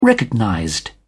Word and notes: recognized: British English

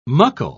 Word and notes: muckle: British English

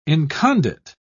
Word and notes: incondite: British English

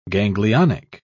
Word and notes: ganglionic: British English

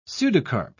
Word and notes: pseudocarp: British English

